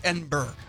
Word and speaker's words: Edinburgh: Edinburgh? – Edinburgh. Well, it looks like Edinburgh, but yeah. – You want to pronounce it like the locals, uh-huh. It's Edinburgh.